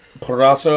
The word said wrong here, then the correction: Purrazzo: Purrazzo, I, believe I is how you say it.